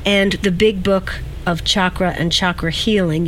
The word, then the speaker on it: chakra: Well, I'll tell you which is the correct pronunciation. It's chakra.